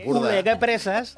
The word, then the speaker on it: Oleguer Presas: Ojo a mi pronunciación del alto Ampurdá. Ulegep Presas. – Lo haces muy bien.